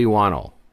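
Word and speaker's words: Whannell: Wannell? How do you say it? – I say Lee Wannell.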